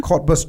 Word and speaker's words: Cottbus: My family lives in Cottbus. Am I saying that right? – Right. Mm-hmm. – Cottbus, Germany.